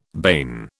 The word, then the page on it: bane: British English